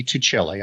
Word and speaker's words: Tuccille: Tuccille.